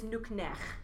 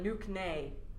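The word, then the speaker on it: nuqneH: And it's nuknech, this nice throaty kind of sound at the end. And when Kor walks into Worf's office, he's like, nuknay. And I'm like, no.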